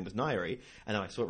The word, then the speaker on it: Ngaire: And the name is spelt N-G-A-I-R-E. – N-G-A-I-R-E. Nagari. – It's Nairi.